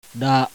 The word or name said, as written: RA